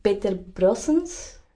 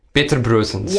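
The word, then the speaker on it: Peter Brosens: When I try to pronounce the name in Dutch, it's Peter Brossens. – Peter Brossens.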